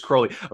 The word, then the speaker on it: Crowley: It is Crowley. Of course, I want to say Mr. Crowley. So it's interesting. I am an Ozzy Osbourne respecter. So it's difficult for me to change the pronunciation.